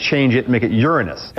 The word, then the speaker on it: Uranus: I call it Uranus unless I'm making a joke. – Uranus. – Strictly speaking, it's pronounced Uranus is the pronunciation.